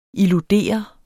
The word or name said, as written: illudere